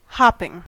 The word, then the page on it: hopping: American English